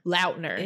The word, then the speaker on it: Lautner: Lautner.